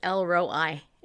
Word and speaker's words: El Roi: He, you know, in the Old Testament, one of his Hebrew names is El Roi. It's spelled E-L and then R-O-I.